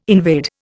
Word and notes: invade: Indian

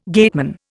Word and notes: gateman: Indian

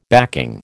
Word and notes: backing: American English

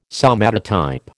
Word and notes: somatotype: American English

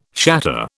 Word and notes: shatter: British English

